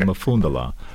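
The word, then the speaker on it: Umfundalai: Mufundala. – Mufundala. – Yep. – Okay. – It's deceiving because it starts with a U, but you first mentioned the M in pronouncing it, okay.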